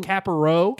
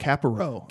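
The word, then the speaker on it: Kapparot: Well, Capro. – Capro. – Capro.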